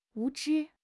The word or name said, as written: 无知